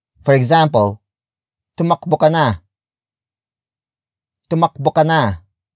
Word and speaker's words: tumakbo: Tumakbo ka na. Tumakbo ka na. The first word is tumakbo, which is spelled as T-U-M-A-K-B-O.